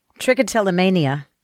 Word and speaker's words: trichotillomania: Trichotillomania. Am I pronouncing that right? – Trichotillomania. – Trichotillomania, they call it.